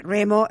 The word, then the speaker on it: Remo: Is That's it, in Italian. Remo and Romolo. – Is the correct pronunciation.